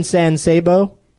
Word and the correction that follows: San Saba: San Saba?